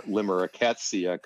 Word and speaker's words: limerickettsia: And everybody has problems pronouncing the book, but it's a word mash, actually. So it's limerick. And then rickettsia, and rickettsia is a type of disease-causing organism. So that's where limerickettsia comes from.